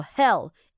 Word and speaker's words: Hel: 的妈妈，我们来看一下他们家他们的家人好不好？Krampus 的妈妈是谁呢？来头可不小，叫做 Hell